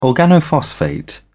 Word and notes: organophosphate: British English

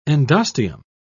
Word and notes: endosteum: British English

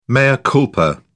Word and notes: mea culpa: British English

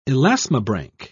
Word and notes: elasmobranch: British English

